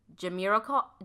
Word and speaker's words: Jamiroquai: Fugees, Killing Me Softly, Fiona Apple, Criminal, and how do you say that? Jamiroquai? – Yeah, you got it.